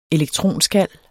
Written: elektronskal

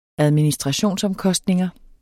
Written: administrationsomkostninger

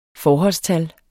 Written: forholdstal

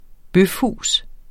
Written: bøfhus

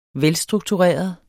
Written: velstruktureret

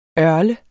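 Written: ØRLEDE

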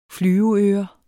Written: flyveøre

